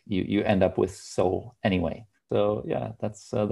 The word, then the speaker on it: Soel: And there's also a wordplay in it because in the naval jargon, um, yeah, a, a, boat has to have a soul. Pronounce it soul yards because, yeah, that's that's uh, what we think makes the most sense.